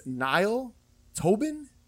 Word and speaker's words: Niall Tobin: And then you've got this actor, I believe it's Niall Tobin is how you pronounce his name.